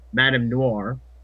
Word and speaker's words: Madame Noir: That would be Baron Nero and Madame Noir.